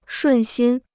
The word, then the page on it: 顺心: shùn xīn